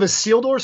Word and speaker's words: Isildur: Heirs of Isildur. – It's Heirs of Isildur. So just think about, you know, like a seal on the beach, and then he's going through a door. Heirs of Isildur.